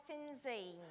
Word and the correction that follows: Finzean: No, it's 12 Geary Road, Fingen!